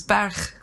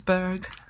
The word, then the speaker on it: Berg: Have I pronounced your surname right? – Kind of. – That's closer than I normally get. – I can't even pronounce it anymore. It's Berg. – Oh. – but no one can pronounce it, so it's Berg.